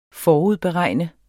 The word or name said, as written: FORUDBEREGN